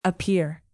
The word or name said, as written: appear